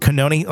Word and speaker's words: Cannone: Or did I say it right? I'm always kind of off on that. – Cannone, I think. – Cannone, like I said, it sounds like an Italian pastry.